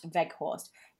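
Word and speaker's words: Weghorst: Is that how we're pronouncing it? Vought. – Vout. – Vout. Veghorst. – Yes. – Vout Veghorst.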